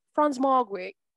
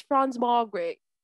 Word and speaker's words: Franz Margaret: I just want them to know that that's how you pronounce my name. It's Franz Margaret.